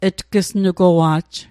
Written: tg'snugowa'j

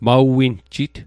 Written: maw_winjit

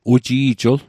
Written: uji'jl

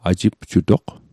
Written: ajipjutoq

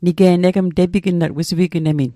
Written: tepignat

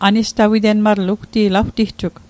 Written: an'stawite'lmatl

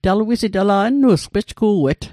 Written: nnu'sgw

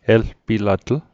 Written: elpilatl

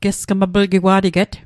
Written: gesgmaplgigwa'teget

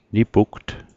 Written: nipugt

